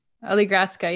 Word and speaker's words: Oligradska: Oligradska, yep.